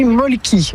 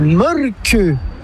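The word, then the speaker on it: Mölkky: Inventé en Finlande en 1996, ce jeu de quilles s'écrit M-O-T-R-E-M-A-L-K-K-Y. – Ça s'écrit Molki, mais ça se prononce Molke. – Le molcu. – Plutôt un E qu'un E, mais pas un U. Comme ça fait molque.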